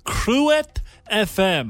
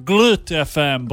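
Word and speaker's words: Groot FM: I tell you, for a month I've been calling them Glut FM, but we found out yesterday from our friend Ricky that Kruet FM means Big FM.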